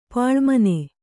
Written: ಪಾಳುಮನೆ

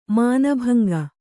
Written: ಮಾನಭಂಗ

